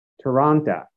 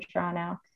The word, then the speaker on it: Toronto: I think people who live in Toronto don't pronounce the T. But, you know, you always hear from folks that are, you know, from our New York office, they'll say Toronto, really like clearly, distinctly pronouncing the T, but you'll know the difference.